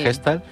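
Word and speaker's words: Gestalt: Ella es psicóloga, formada en terapia Gestal. Lo he dicho bien, Zaida, sí. – Gestal.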